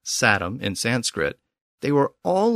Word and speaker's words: satem: And in Sanskrit, the word was satum, spelled S-A-T-E-M.